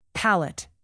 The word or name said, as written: palette